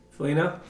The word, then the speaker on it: Foligno: Yeah, Foligno.